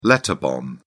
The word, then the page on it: letter bomb: British English